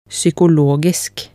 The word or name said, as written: psykologisk